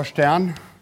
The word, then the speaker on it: Stern: Sieht ganz harmlos aus, so ein paar Förmelchen, steckt aber einiges drin, weil F Stern, das schreibt man das mit Stern, also wirklich, man spricht es auch Stern, das ist der F Stern G, zwei Funktionen gefaltet ergeben eine Funktion.